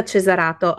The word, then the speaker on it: Cesarato: Monica Cesarato.